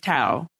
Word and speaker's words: Tao: And he has a friend named, now, before I even butchered a friend's name, his friend's name is Tao.